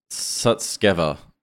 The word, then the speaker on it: Sutskever: I mispronounced the name of Ilya before. It's Sutskever. Ilya Sutskever. He's a chief scientist at OpenAI.